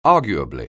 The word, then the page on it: arguably: anglais